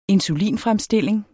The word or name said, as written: insulinfremstilling